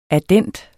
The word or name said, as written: addend